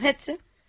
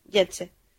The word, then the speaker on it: Getse: Entonces yo lo pronuncio como Getze, pero en realidad se pronuncia como Getze.